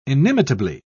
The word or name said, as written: inimitably